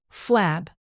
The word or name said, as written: flab